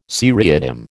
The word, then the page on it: seriatim: American English